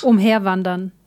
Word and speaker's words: umherwandern: Perambular es Umherwandern.